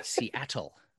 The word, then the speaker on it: Seattle: Seattle. – yes, that's right, Seattle.